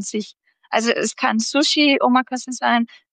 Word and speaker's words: Omakase: Was vielleicht viele schon gehört haben, ist Omakase, also europäisch ausgesprochen Omakase, also quasi Chef's Choice.